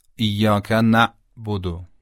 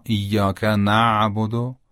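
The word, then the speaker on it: إياك نعبد: تلفظ غلطش رو اول انجام میدم، بعد تلفظ صحیحش رو. ایاک نعبدو، این غلطه. و اما ایاک نعبدو، ایاک نعبدو، این صحیح و درسته.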